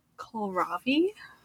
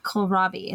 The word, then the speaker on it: kohlrabi: What's kohlrabi? – Kohlrabi? – Kohlrabi. It's spelled like Kohl's and then R-A-B-I. Kohlrabi.